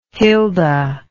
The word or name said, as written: hilda